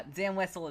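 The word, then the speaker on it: Zam Wesell: It's Zam Wessel. – Okay. – Is that the right pronunciation? – Yeah, it is. It's the right pronunciation. Yeah, Zam Wessel is right.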